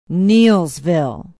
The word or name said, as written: Neillsville